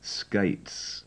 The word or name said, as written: skates